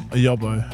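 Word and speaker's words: yobbo: The loudmouth who's a larrikin, who likes the sound of his own voice, is a yabo. – Yobo. – Often a bit of a troublemaker. What's it? How do you pronounce it? – A yobo. – A yobo.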